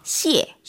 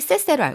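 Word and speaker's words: 시에 (谢谢): 시에. – 시에. – 많은 분들이 쎄쎄로 알고 있어요. – 쎄쎄. 아닙니다.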